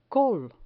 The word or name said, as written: col